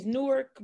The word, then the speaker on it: Newark: It's Newark, but people say Newark. I don't like having to literally thoroughly pronounce words because now it says very weird. It sounds very weird to me because I just say Newark.